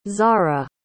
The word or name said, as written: zahra